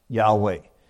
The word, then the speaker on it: Yahweh: And that's a technical word meaning this word that is usually pronounced Yahweh.